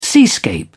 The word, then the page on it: seascape: British English